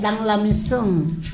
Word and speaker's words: Dans la maison: Dans la maison. – La has dicho muy bien.